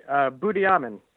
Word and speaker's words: Budiaman: Budiaman.